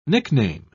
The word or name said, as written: nickname